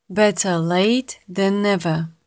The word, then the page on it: better late than never: slow